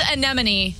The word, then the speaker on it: anemone: It's anemone, but what it's spelled the same way Yosemite is.